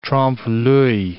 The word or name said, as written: trompe l'oeil